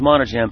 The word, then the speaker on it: Monadjem: It's last name is Monajum. M-O-N-A-D-J-E-M.